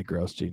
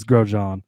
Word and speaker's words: Grosjean: I always say Grosjean because that's how it's spelled, but it is Grosjean.